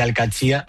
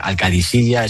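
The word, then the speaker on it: Al-Qadsiah: ¿Cómo se pronuncia? – Bueno, se suele decir Alcachía. – Alcachía. – Pero también hay gente que dice Alcadisilla. Es que es difícil, ¿eh? Ah. No es fácil, pero Alcachía es lo más fácil.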